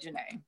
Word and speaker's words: Jeneé: Everything but Jeneé.